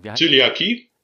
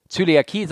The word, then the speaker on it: Zöliakie: Also ich hatte einen Kollegen, der hat äh, also nicht nur dieses ähm, äh, Lifestyle-Zöliakatie oder Zylo, ich kann das nicht aussprechen. – Zöliakie? – Zöliakie, sondern der hatte, der hatte also ähm, wirklich eine, eine richtig strenge ähm, hier Glutenschwäche sozusagen.